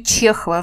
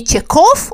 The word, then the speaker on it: Чехов: А во Франции она тоже начинает писать романы, причем на французском, и даже пишет биографию Чехова в желании сделать его ближе французам. И пытается на всех радио и видеопрограммах объяснить французам, что неправильно они произносят его фамилию. Не Чехов он, а Чехов.